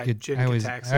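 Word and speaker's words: Jin-Gitaxias: Or something like Jin Jitaxius. Is that how you say it? – Yeah, Jin Jitaxius.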